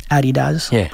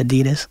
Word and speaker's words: Adidas: They were the first non-athletic entity to ever get sponsored by an athletic company, which was, of course, Adidas, probably how you guys pronounce it over here. We say Adidas.